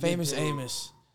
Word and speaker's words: Famous Amos: Is it pronounced Famous Amos? – Yeah. – Ooh, good take. – Famous Amos.